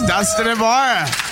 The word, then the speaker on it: Dustin Ybarra: Yes, Dustin Ybarra.